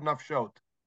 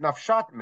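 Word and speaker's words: נפשת: Look at the word nafshot. It's spelled without a vav, which means you could read it nafshat meit. But we read nafshot, and therefore Rabbi Kiva says that's how you interpret it, nafshot, based on the proper traditional reading, right? – Yes.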